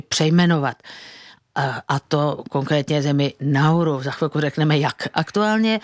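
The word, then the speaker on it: Naoero: Nové jméno tedy není Nauru, ale píše se to Nauero. Prezident Adeang to vyslovuje něco mezi Nauru a Nauero. To O na konci možná dokonce jako by skoro bylo třeba přehláskované, něco jako v němčině.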